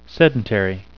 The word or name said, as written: sedentary